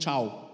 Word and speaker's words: Cho: You know, I just thought about the story of Yonggi Cho. His name is actually Yonggi Chow. That's how to pronounce it.